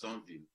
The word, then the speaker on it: Sainvil: My name actually is a common Haitian name. It's like my actual name. Same as it's pronounced Sainvil.